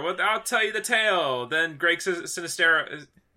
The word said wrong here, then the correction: Sestero: Greg Sestero, if I can say his name right,